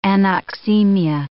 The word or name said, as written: anoxemia